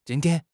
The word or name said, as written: jin1 tian1